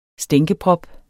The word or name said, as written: stænkeprop